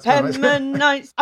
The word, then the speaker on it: Agapemonites: Agapemonites. – I'm pretty sure that's how you pronounce it, Agapemonites.